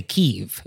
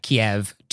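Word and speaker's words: Kyiv: So I think it's also important to remember that some pronunciations are also political and like charged, like the city of Kiev. You could say Kiev if you wanted to do the Ukrainian pronunciation. – Oh, wow. – And so you're actually making a choice. If you say like Kiev to a Ukrainian person, then you're like, oh, I'm going to say the Russian pronunciation.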